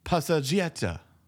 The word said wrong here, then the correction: passeggiata: Probably saying it wrong. Let's have this. – Passeggiata. – Passeggiata.